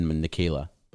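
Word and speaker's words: Nickayla: I don't know if it's spelt that way, but it's said Nikayla. Or it's spelled it's pronounced Nikayla. Sorry. – The weirdest way I've seen Nikayla spelled. – It's spelled like Nick, like N-I-C-K, and Ayla.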